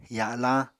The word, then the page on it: ya7lañ: Apatani